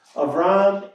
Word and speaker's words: Avram: Now, all of this that I have said is illustrated by today's passage, today's story that we heard of Avram, with a Hebrew pronunciation, Avram and the Lord Yahweh.